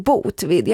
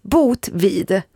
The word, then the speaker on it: Botvid: Jag har alltid så svårt för namn som Botvid. Jag vet inte hur det ska uttala. Alltså, Botvid. – Botvid. – B-O-T-V-I-D. – Ja, det låter väl korrekt.